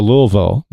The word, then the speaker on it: Louisville: We're trying to get people to go down to Louisville this September. – Really? – I like how you say that properly. – I might be interested. – Thank you for picking up. – Not Louisville.